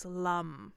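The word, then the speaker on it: Lam: But the root word for the Muladhara chakra, is lum. – Lum. – It's spelled like lamb, it's pronounced like thumb.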